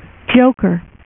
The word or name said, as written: joker